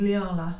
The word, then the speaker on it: Liala: But this one is called Liala, I think that's how you pronounce it, but it's L-I-A-L-A, so Liala is how I would say it.